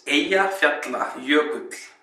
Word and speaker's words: Eyjafjallajökull: So actually, this is the name of the volcano pronounced correctly. – So here it goes. It's Eyjafjallajökull. That's Eyjafjallajökull.